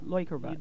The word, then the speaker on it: Leukerbad: The German phonic EU is pronounced Oi. – There you go, Leukerbad.